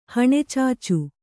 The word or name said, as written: ಹಣೆಚಾಚು